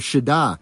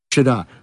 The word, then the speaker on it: Siddha: So in Hindu, you have this idea of Shiddha. So S-I-D-D-H-A. Yeah, that's how you say it. S-I-D-D-H-A. Yeah, that's Shiddha. – Right. Shiddha.